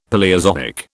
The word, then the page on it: Paleozoic: British English